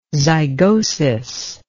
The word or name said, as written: zygosis